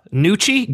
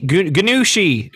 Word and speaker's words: gnocchi: Gnocchi? Gnocchi.